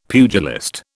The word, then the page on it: pugilist: British English